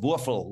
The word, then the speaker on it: Wurfl: My guess is Jack Wurfl, W-U-R-F-L. Wurfl would be the German pronunciation, Jack Wurfl.